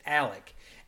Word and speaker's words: Alex: He spells his name A-L-E-X, but pronounces it Alec.